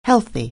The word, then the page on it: healthy: anglais